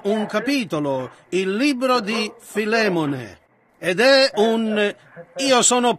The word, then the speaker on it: Filemone: Così Filemone, ha detto qualcuno lì in fondo, il che penso ne sia realmente la pronuncia corretta.